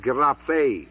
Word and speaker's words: graphe: The word Scriptures is usually from the word graphe, G-R-A-P-H-E, long E, from which we get graphics.